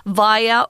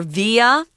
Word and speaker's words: via: Now, before cruising back to daylight via or via, you can pronounce this word both ways, guys. It just means through that or by this way. Uh, I say via more often, via, via rail.